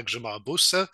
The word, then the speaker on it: Grzymała-Busse: I am delighted to welcome to the podcast today Professor Anna Grzymała-Busse.